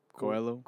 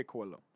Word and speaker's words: Coelho: How do you pronounce his last name? Paulo Coelho. – Coelho? Let's say Coelho.